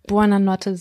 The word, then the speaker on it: Buona notte: Ich möchte jetzt hier an der Stelle Buona Notte sagen.